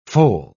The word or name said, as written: foal